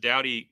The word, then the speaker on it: Doughty: is it pronounced Doughty? – That's what I believe. – That's how it's spelled. So Judge Doughty appears to have issued a nationwide injunction.